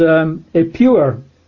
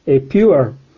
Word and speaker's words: Ipuwer: This sage or wise man, his name was Ippur. That's an Egyptian name. We would spell it I-P-U-W-E-R, Ippur.